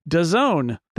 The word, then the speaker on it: DAZN: It is an event that is happening that a lot of people don't even know exists, in part because it is primarily on DAZN. That is how you pronounce DAZN, by the way.